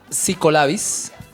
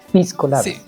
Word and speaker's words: piscolabis: Está psicolabis. – Piscolabis.